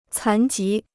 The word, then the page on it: 残疾: cán jí